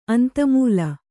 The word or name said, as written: ಅಂತಮೂಲ